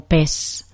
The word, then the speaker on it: pez: En un español latinoamericano dirían más algo como PES pero yo no tengo esa pronunciación, entonces no te puedo decir 100%. Yo intuyo que es pez en, en Latinoamérica.